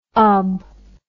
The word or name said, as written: ob